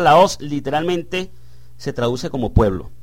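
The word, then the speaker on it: laos (λαός): encontramos en primer lugar el término laos o laos. Aquí aparece con acento en la O en el diccionario Strong.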